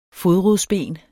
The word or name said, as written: FODRODSBEN